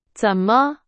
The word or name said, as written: 怎么